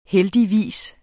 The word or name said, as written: heldigvis